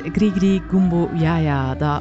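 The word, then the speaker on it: Gris-Gris Gumbo Ya Ya: Je hoorde Dr. John met Grigri Gumbo Yaya. De titel van het liedje wil ik blijven uitspreken totdat ik het correct heb. Ik krijg een thumbs-up van Wim, dus ik zou het wel goed hebben.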